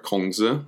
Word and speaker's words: Kongzi: Uh, Confucius the man, uh, the Chinese word for it, Kongzi, uh, again, that's the 20th century um, Northern Standard Ma- Mandarin Chinese pronunciation of his name.